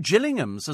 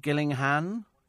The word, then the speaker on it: Gillingham: Pronounced differently, though, says Mark. Do you think it Gillinghan? Gillingham. Oh, there we go. I wasn't far off it. So Gillingham, Kent, Gillingham, but both spelt the same.